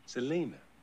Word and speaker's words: Selina: My name's Selena. Selena Darcy. – Selena? – That's right. – How do you spell it? – S-E-L-I-N-A. – Oh, Selena. That's a lovely name. – How'd you say it? – Selena.